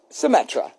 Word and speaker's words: Symetra: Symetra.